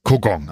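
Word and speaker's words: Kogong: Kugong, absolut richtig.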